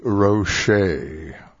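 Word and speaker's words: Roché: Now to Betty Roche, R-O-C-H-E, with an accent on the E, 1952 for Columbia Records.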